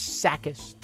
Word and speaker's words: Tsakis: Chris Sakis, T-S-A. K-I-S. The T is silent.